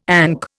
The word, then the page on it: ANC: Indian